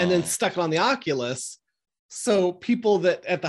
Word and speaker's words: Oculus: I just saw Camp yesterday brought their, is it an Oculus? Is that how you say it? – Yeah, the Oculus, the VR.